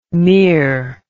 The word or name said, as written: mear